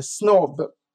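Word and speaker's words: snob: Utan adel, och det uttalade snobb.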